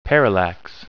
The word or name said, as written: parallax